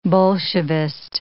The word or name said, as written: Bolshevist